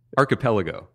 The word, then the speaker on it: archipelago: Archipelago? How do you say it? – Archipelago. – Oh, archipelago.